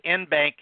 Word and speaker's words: en banc: It's French, it's Latin, en banc, en banc. So anyway, it's en banc is how I pronounce it.